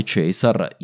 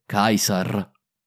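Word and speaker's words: Caesar: Parleremo anche di come la pronuncia è cambiata, cos'è la restituta per esempio, secondo la quale Cesar in realtà si pronuncia più correttamente Kaiser.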